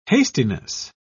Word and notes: hastiness: British English

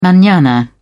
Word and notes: manana: British English

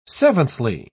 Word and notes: seventhly: British English

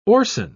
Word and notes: orcin: British English

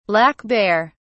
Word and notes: black bear: British English

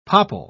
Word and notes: popple: British English

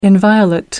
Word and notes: inviolate: American English

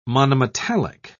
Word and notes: monometallic: British English

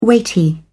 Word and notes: weighty: British English

